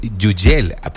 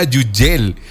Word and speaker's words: Hugel: Si es Yugel, porque él así dijo. Así dijo el propio Hugel, que la pronunciación correcta de su nombre era Yugel.